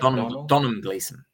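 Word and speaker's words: Domhnall Gleeson: And Donald Gleason. I don't know if I'm pronouncing that correct. Is that how you say it? – Donald Gleason.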